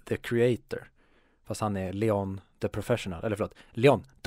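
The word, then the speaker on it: Léon: För det är väl det han heter? – Uh, nej, han heter ju Leon.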